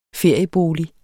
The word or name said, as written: feriebolig